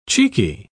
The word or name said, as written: cheeky